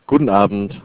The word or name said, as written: Guten Abend